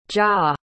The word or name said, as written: jah